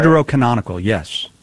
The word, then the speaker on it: Deuterocanonical: Was the, as you guys call them, Deuterocanonical? I think that's how you say it. – Deuterocanonical, yes. Yes.